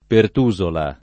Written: Pertusola

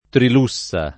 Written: Trilussa

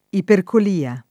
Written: ipercolia

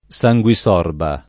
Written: sanguisorba

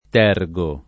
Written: tergo

